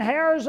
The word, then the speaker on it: heirs: He said, today, church, I want to talk about being heirs of God. I said, no. I said, I was going to put a sign that said silent H. It's a silent, heirs, not hairs.